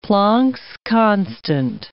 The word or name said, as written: Planck's constant